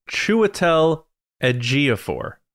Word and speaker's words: Chiwetel Ejiofor: All right, so it's Chiwetel Ejiofor. That is how you pronounce it.